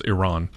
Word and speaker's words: Iran: But yeah, the proper pronunciation is Iran.